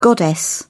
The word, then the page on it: goddess: British English